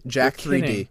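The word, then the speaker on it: Jack3d: Jack 3D is it's spelled Jack like the name Jack J A C K 3D, so jacked.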